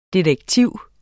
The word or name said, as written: DETEKTIVS